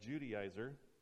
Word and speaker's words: Judaizer: That word there is spelled weirdly. That's, you say that, Judaizer.